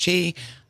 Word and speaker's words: Qi: So it's the case of user education and understanding, okay, that means that sign, which is Qi, QI, pronounced Qi, that means that that's compatible with my phone.